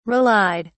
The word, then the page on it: relied: British English